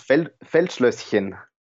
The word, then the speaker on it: Feldschlösschen: Can you pronounce it for me, please? It's so complex. – It's Feldschlösschen. So it means like field castle.